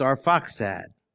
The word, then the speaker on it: Arphaxad: And so there's names like Arphaxad, which looks like Arpakshad, but is pronounced Arphaxad.